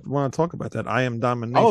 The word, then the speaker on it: I Am Domination: It's called I Am Domination. – Yeah, it's I Am Domination. And thank you, that's why we fuck with you, dude, because you say it right. Everybody's like, oh, Domination. Like, no. – I'm really right.